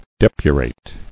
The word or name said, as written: Depuration